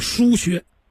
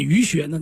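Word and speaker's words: 腧穴: 啊，也有人念鱼，其实叫疏穴。啊，有人偏念鱼穴，那没办法，是吧？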